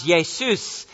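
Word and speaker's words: Jesús: Now, today, sometimes you get that name, although it's pronounced Jesus, in the Spanish culture, and it is a name that is still used there.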